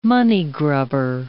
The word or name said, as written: money-grubber